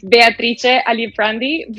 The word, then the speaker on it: Beatrice Aliprandi: And just to clarify me, and I know you just corrected me before, and I wanted to attempt, but can you pronounce your name the way that it's pronounced in Italian? – It's Beatrice Aliprandi, but that's perfect how you pronounce it anyway.